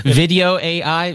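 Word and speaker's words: Vidyo.ai: No, I'm using a tool called, how to pronounce it with all these Video AI, VIDYOAI.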